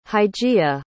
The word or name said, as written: hygeia